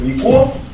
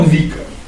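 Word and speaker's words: Vico: Ну, его иногда произносят как Вико. Вообще он Вико.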